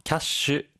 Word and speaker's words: キャッシュ: 캐 시 는 キャッシュ 라 고 다섯 박 자로 발 음합 니 다.